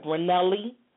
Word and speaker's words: Ranelli: Am I pronouncing your first name right? Ranelli? – Ranelli is perfect.